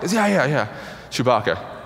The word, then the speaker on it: Chewbacca: But it's hilarious because he pronounces it Shabaka, which is I mean sounds like a like a cologne or something, you know, like a like a women's fragrance, Shabaka, right? Um, that's how he pronounces it.